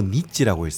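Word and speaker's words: 니찌 (日): 원래 날일자는 오음으로 니찌라고 했습니다.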